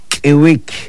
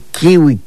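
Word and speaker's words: k'iwik: Es Kiwik, Kiwik, Koosh Kiwik. Vamos a la plaza. Pero la gente se acostumbra a decir kiwi.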